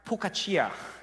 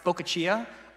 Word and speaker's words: focaccia: Unfortunately for me, in Korean, this is pronounced pokachia.